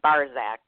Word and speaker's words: Barczak: It's Polish, so it's always difficult, but the C is silent, so it's Barczak, like B-A-R-Z-A-K, even though there is a C in the middle there.